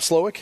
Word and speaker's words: Slowik: Uh, Say it one more time Big Bob Slowik? – Yeah, Slowik.